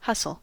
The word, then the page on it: hustle: American English